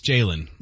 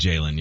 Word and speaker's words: Jalon: J-A-L-O-N. – I know, I think it's Jaylon. – It is. – They always said Jaylon, yeah. – Why do you say Jaylon then? – Cause it's J-A-L-O-N.